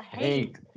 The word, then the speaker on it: haint: It's called a haint. It's a haint. Okay. No. Haint. It's a mountain. H-A-I-N-T, I believe is how you spell it, but I'm dyslexic, so don't take me at that.